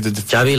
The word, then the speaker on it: Xavi: Sorry, Xavi like chocolate. – Yes, okay, thank you very much. – Chocolate. Llavors jo quan estic trista em poso Xavi parlant anglès i em recupero, tu.